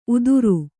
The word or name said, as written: ಉದುರು